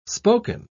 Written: spoken

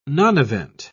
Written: nonevent

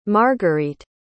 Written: margarete

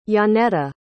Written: Janeeta

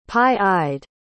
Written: pie-eyed

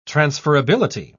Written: transferability

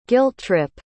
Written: guilt-trip

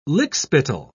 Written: lickspittle

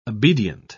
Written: obedient

